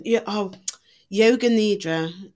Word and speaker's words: yoga nidra: But meditation, yoga nidra, I don't know if that's how you pronounce it. Yes, it is.